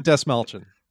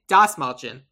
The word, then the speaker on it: Dasmalchian: Stanley Ipkiss is going to be played by, I'm going to butcher his name, David Basmalchian. – David Dasmalchian. – Dasmalchian.